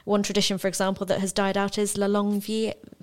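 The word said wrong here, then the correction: La Longue Veille: I'm going to say this wrong. – La Longue Veil. – La Longue Veil. Thank you.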